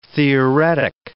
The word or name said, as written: theoretic